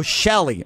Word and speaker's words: Chellie: Am I saying your name right? Or did they just spell it wrong? Oh, Shelly. Okay, that's spelled C-H-E-L-L-Y. That's why I called you Chelly. – But oh, my gosh. My spelling is C-H-E-L-L-I-E, and nobody ever gets that right.